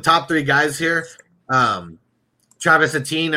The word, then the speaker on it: Etienne: And then we got, how do you pronounce his name? – Etienne. – Etienne, okay. And he's the guy from Clemson.